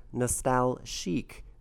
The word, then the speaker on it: Nostalchic: That's by a guy named Lapalux off his record Nostalchic, but it's spelled C-H-I-C.